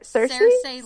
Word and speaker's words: Cersei: And she's inspired by, if I say her name right, Cersei? – Cersei Lannister.